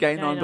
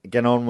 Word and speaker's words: Gagnon: No, you said Gainon, but I want to say Duganon. – Ganon. Yeah, Ganon. It's G-A-G-N-O-N. Gagnon. – Let's call him Gagnon. – All right. So Gagnon was disqualified.